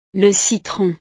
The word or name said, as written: le citron